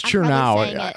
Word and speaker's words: Chernow: I think you said his name. – I always do. How do you think you say it? – It looks like it's spelled like Chernow. – You're right. It's Chernow.